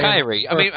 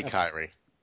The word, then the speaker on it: Khiry: Kyrie. It's not that hard to say Kyrie. It really isn't. – It spells K-I-R-Y? – K-H-I-R-Y. – It's got to be Kyrie. – It's Kyrie.